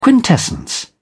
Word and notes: quintessence: British English

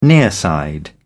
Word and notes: nearside: British English

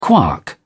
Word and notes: quark: British English

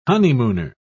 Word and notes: honeymooner: British English

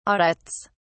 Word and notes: audits: British English

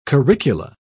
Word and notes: curricula: British English

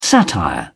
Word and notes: satire: British English

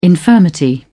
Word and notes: infirmity: British English

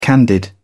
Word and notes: candied: British English

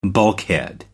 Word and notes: bulkhead: American English

